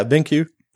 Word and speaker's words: BenQ: And I'm not sure if I'm pronouncing this company name right, but BenQ? – Yeah, BenQ.